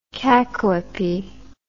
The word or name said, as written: cacoepistic